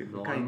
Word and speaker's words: Hawkwind: C'est parce que c'est Hawkwind.